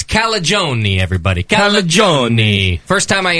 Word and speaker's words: Calagione: And he just said, it's like bologna. – Perfect. – And that's how I've remembered it ever since. – Perfect. – Calagione bologna.